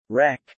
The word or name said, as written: wreck